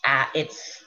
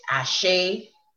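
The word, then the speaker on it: Àṣẹ: And in Brazil, it's Axe, A-X-E. In Cuba, it's Ache, A-C-H-E. You know, in this part of the world, it's Ashe, right? People spell it A-S-H-A-Y or A-S-A-Y. You know, the original spelling, A-S-E, with a dot under the S and a dot under the E.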